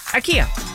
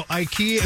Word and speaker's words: IKEA: People were shocked the other day to find out that that's not the correct way to pronounce I-K-E-A. The actual pronunciation is IKEA.